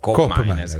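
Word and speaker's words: Koopmeiners: Qui parliamo fiammingo, che è l'olandese, per la cronaca colgo l'occasione per dire che di Cop Miners, la doppia O di Cop Miners invece si legge U. – Oh. – No, O si legge O, invece O si legge U. Quindi Cop Miners è corretto chiamarlo Cop Miners.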